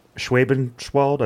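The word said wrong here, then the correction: Schwäbisch Hall: Schwabisch Hall.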